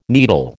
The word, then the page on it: needle: American English